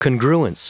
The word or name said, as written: congruence